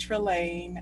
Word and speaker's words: Trelane: It's pronounced Trelane.